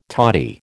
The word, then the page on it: toddy: American English